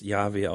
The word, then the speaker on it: Jahwe: Im alten hebräischen Text würde hier ähm, Jahwe stehen, also J-H-W-H. Und es ist höchstwahrscheinlich, dass es Jahwe ausgesprochen wurde.